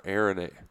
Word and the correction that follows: Aranda: Aranda. – Aranda. Damn it. There's too many A's in there.